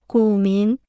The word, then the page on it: 股民: gǔ mín